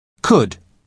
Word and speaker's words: could: The man at the lost luggage desk uses could you to Carolina because he's being polite. Listen to the pronunciation. Could. Could. You spell it C-O-U-L-D, but the letter L is silent.